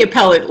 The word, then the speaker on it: appellate: Um, you know, even in the appellate level, I can't even believe I pronounced that wrong.